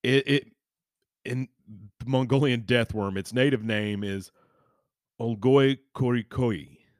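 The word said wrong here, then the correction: Olgoi Khorkhoi: Korkoi. I butchered that. Olgoi Korkoi,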